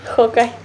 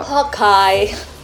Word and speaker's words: Hawkeye: Hawkeye. Pero digo... Hawkeye.